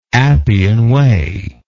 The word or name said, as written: appian way